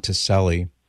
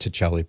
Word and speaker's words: Tacelli: So the author is Dr. Peter Kreeft, K-R-E-E-F-T, co-authored with Father Ron Ticelli, T-A-C-E-L-L-I. Yes, I know it should be pronounced Ticelli, but everybody says Ticelli, so he went with that.